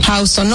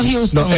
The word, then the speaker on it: Houston: Tú quieres saber cuándo es una persona nativa de Nueva York, es cuando dice esa calle correctamente. ¿Cómo se dice? Houston. Houston, no Houston.